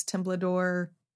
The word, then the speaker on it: Temblador: So for my info, you can find me at Alex Temblador on any social media. My last name is spelled T-E-M-B-L-A-D-O-R.